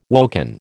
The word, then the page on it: woken: American English